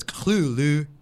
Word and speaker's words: Cthulhu: The TH or the T kind of becomes more of an L. – L with phlegm. – So it's... – Cthulhu.